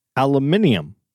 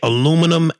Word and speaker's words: aluminium: No, aluminium. – What? – That's how they say it in London. – They say aluminum, aluminium.